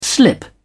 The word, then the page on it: slip away: British English